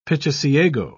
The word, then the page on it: pichiciago: British English